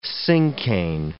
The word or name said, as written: cinquain